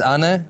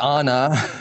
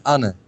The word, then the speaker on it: Anne: So you pronounce it Anna. Anna is a man. – Well, it's not Anna. – Oh, okay. – It's Anna. – Anna. – Well, that's, Anna. That's the fine stuff of different languages.